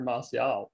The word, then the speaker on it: Martial: So I just wanted to go through Martial, who rhymes with martial arts. Don't check that one. 0-0-1-0-1-0-0-0.